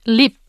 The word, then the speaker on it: 립: And if this syllable 립 doesn't come first but comes after another syllable, it's written and pronounced as is, 립.